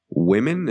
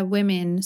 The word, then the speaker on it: women: And by the way, sometimes people confuse the pronunciation of woman in the plural, which is women. And is that how you say it in British English? – Yeah, women. So this is really confusing, I think, because literally you change one letter, but even the first sound changes, which doesn't really make sense.